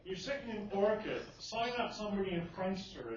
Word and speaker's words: Orkut: Or think about Orkut, or no, actually no, it's pronounced Orkut. I learned that not too long ago.